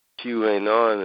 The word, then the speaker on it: QAnon: q ain't on and it, if you say it out phonetically it's the word canon